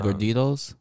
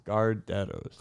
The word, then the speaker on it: Gardettos: Gorditos? – Gardettos.